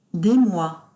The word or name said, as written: des mois